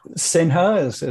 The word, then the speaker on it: Sinha: I may have butchered your pronunciation of your last name. Maybe you can... – Sinha is great. No, that's perfect.